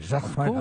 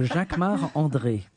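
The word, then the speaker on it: Jacquemart-André: Jacques-Marc-André. Of course, because I'm French. So Jacques-Marc-André.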